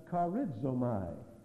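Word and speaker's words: charizomai (χαρίζομαι): This word, there is another word in the New Testament that looks like this, and it's pronounced charizomai, C-H-A-R-I-Z-O-M-A-I.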